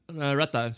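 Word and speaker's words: Ratha: And Rata, Retha, sorry, I'm Indian. I'm supposed to pronounce that right.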